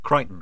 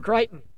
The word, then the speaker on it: Crichton: But you found a remarkable connection between Belgravia's Amelia, is it Crichton? I don't know how to pronounce it. – Crichton. – Crichton.